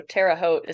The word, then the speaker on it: Terre Haute: It's uh, the French fur trappers got there first, so Terre Haute is how you say it. But I've very rarely heard anyone not from there get it right.